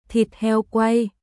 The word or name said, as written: Thịt heo quay